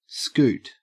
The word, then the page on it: scoot: Australian English